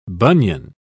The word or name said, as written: bunion